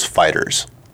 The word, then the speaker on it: FighterZ: I think they actually put a thing out when it came out on PS4 and Xbox that it is Fighters.